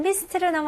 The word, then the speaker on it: mist: 다음은 mist로 넘어갈게요. 네, mist는 발음은 mist. 오케이, 여기서 i 발음입니다. 우리나라 사람들은 i 발음 좀 어려워해요.